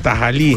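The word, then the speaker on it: tahalí: O tajali. – Con H, sí, tajali, mejor dicho, porque tiene acento en la I.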